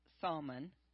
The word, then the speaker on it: Solomon: And Solomon begot, it's not, it's Solomon, the L is silent.